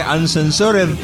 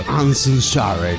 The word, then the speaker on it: Uncensored: Digo, el pronunciar en inglés no es lo mío, pero estoy seguro que Uncensored debe estar cerca de ahí, pero... – Uncensored. – Ahí está mejor.